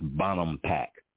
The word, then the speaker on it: Bonampak: That's Bonham Pack. B-O-N, Bonham, A-M, Bonham Pack, P-A-K, Bonham Pack.